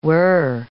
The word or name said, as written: were